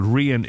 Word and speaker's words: Rian: Pronunciation, for example, Rian is pronounced R-E-E-A-N. – Oh yeah, makes sense, yeah. – And then Kieron is K-E-E-R-O-N.